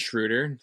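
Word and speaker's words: Schröder: And I have to also shout out Dennis Schroeder, rhymes with shooter.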